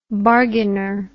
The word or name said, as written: bargainer